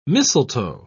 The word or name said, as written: mistletoe